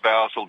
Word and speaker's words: Basil: Yeah, Basil is pronounced Basil the English way.